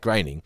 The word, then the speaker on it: Groening: It's Matt Groening.